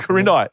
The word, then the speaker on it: Quirindi: Is it Quirindi? Q-U-I-R-I. Quirindi, yep. Quirindi. Yep, beautiful. There we go. I was born in Quirindi, says Andy, a small town near Tamworth.